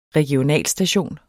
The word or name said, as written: regionalstation